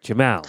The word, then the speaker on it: Jamal: It's like the first name is pronounced Jamal. Like Jamal. – Phonetically, it's like Jim and Al. – Jamal.